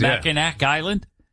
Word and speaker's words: Mackinac: Goes to Mackinac Island. – Oh, no. Mackinac Island. First of all, yeah. – It's spelled. Why do they spell it Mackinac when it's Mackinac?